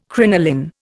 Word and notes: crinoline: Indian

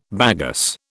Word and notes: bagasse: British English

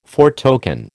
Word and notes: foretoken: American English

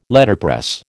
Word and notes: Letterpress: American English

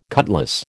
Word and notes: cutlass: American English

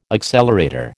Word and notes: accelerator: American English

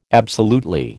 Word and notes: absolutely: American English

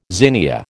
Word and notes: zinnia: American English

